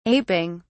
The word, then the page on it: aping: British English